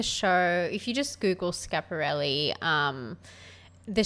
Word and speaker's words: Schiaparelli: Yep, so it's pronounced Schiaparelli. Okay. Some people will say Schiaparelli, mm-hmm. but when you see an S, a C and an H, it's pronounced Sch.